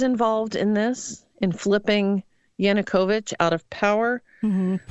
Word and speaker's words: Yanukovych: Let me make sure I say his name right Yanukovych.